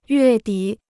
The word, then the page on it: 月底: yuè dǐ